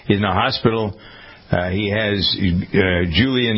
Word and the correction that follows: Guillain-Barré: Yeah, Guillain-Barré syndrome, the neurological condition.